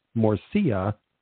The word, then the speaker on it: morcilla: It says morcilla. Is it morcilla? Morcia. Okay, now they're telling you how to pronounce it. Morcia, I guess.